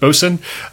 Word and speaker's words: boatswain: You have the ship's mate, William Blake, the ship's bosun, or spelled boatswain, but pronounced bosun, John Shaw.